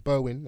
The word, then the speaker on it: Berwyn: Is it Berwyn or Berwine? I don't know. – Berwyn, there we go.